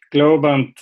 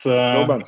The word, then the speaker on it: Globant: Uttalar man ja. så? – Globant. – Globant, ja.